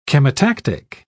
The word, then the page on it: chemotactic: British English